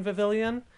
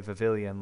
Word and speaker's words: Vivillon: I watched My Hero Academia recently, so I want to say Vivillon, like Lemillion.